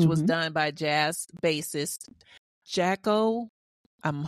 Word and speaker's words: Jaco: I looked up the pronunciation because I wasn't trying to be caught out there this time. And I think I think Jaco is right.